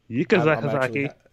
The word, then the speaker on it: Yuka Sakazaki: Yuka Sakazaki, take that.